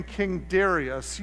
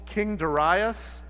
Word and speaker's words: Darius: I pronounce his name King Darius. You may have learned in Sunday school, King Darius. The Hebrew allows for either pronunciation is acceptable.